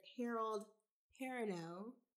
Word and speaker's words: Harold Perrineau: Terrence Howard, Small Ethan, Nia Long, and Harold Perrineau.